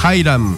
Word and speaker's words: Hiram: Dile al pato que su inglés es pésimo, me duelen los oídos, se pronuncia Hiram.